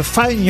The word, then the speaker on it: Fine: Yes, you have to say the five young cannibals. – Non, pas five, fine.